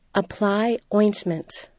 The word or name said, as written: apply ointment